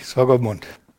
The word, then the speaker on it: Swakopmund: Swakopmund, Namibia, spricht man das so? – Richtig, Swakopmund.